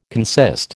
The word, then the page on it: consist: American English